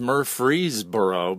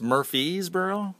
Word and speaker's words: Murfreesboro: We are in Murfreesboro, Tennessee, and it's spelled Murfreesboro, but yes, is it pronounced Murfreesboro? – No, it's Murfreesboro.